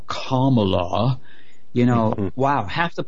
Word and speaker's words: Kamala: Also, I've said before how Kamala Harris, turns out I was mispronouncing her name, she now insists that it's Kamala Harris. And what does that rhyme with, by the way? Kamala, Obama.